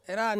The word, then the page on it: ran: Ansus